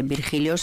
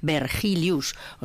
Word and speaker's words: Vergilius: Salían letras de filósofos como Virgilio, solo que escrito en castellano, Virgilio, y no se dice Virgilio, se dice en latín Vergilius, o sea que, y así suma y sigue.